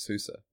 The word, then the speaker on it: SUSE: He was like German or whatever, so he pronounced it SUSE.